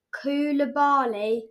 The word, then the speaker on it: Koulibaly: I can't pronounce Koulibaly. – This one either. – Koulibaly.